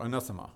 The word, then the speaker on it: anathema: Let him be anathema. Or anathema, as it can also be pronounced.